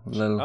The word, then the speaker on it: Lille: It was, I don't know how to pronounce it. Lille? L-I-L-L-E. Lille.